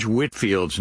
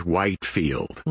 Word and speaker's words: Whitefield: Well, Pastor Greg, many people, if they saw George Whitefield's name written on a piece of paper, they'd probably pronounce it George Whitefield. Mm. You know, the name's just not all that familiar to them, and not familiar enough to know that it's Whitefield.